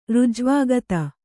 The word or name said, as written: ಋಜ್ವಾಗತ